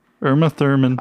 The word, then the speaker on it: Uma Thurman: Uma Thurman from... I don't know if I'm pronouncing the last, but from Kill Bill. – Uma Thurman.